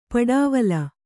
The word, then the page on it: ಪಡಾವಲ: paḍāvala; Kannada